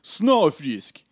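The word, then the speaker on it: Snøfrisk: And then F-R-I. S-K. – Yeah. – Yeah. – Spelled like Frisk. – Zero Frisk. It's Snow Frisk. – Yeah, that's correct. That's correct.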